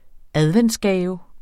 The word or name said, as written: adventsgave